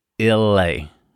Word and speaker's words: Islay: I think I got three emails or four emails last week, plus a few DMs of people that said, guys, it's not Islay, it's Illay. Like, it's not, because we talked about the the Chattanooga finished Scotch Cask last week, and it's Islay or Elay or whatever it is, but it's a silent S in there.